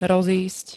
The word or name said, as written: rozísť